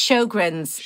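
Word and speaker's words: Sjögren's: Yeah, so it's Sjogren's.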